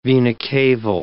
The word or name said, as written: vena caval